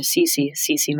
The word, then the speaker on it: Cece: As usual, it's really funny because when I say with me, Victoria Cece, my name pronounced in American English, it rhymes. Victoria Cece, less rhyme.